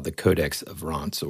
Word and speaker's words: Rohonc: Okay, now, when you look this up, this book, the way it's spelled is R-O-H-O-N-C. So whenever I found out about this book, I thought it was the Rohans Codex, right? Mm-hmm. But it's actually Rohans, and that's how you pronounce it.